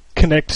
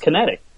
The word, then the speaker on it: Kinect: Everybody thought it was Kinetic. They're like, is this the Microsoft Kinetic? I'm like, damn, that's bad marketing when nobody can pronounce what you just named your system.